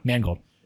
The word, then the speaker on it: Mangold: Yeah, Mangold.